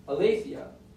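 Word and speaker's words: aletheia (ἀλήθεια): And the word in the Greek there for truth is pronounced aletheia.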